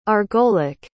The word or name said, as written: argolic